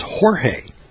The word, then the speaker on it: Jorge: that name in Spanish is Jorge. Jorge. And I thought, well, that's kind of strange, and it's spelled G-E-O-R-G in Spanish.